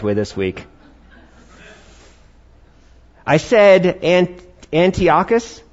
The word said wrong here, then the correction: Antiochus: I'm going to repronounce that right now, because you have so much grace for me. I'm going to say Antiochus this time. All right, Antiochus, all right? It's all about which syllable you accent, all right?